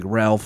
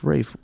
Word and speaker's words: Ralph: If you look at it, you just read it, it looks like Ralph Fiennes. – Yep. – But apparently it's pronounced Rafe.